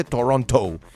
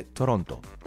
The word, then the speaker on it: Toronto: Ya me corrigieron aquí y me dijeron, no se dice Toronto. Se dice Toronto.